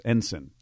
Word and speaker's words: Ensign: By the way, I'm being corrected by people with military knowledge. It's Ensign. – Ensign. – I guess is how you pronounce that.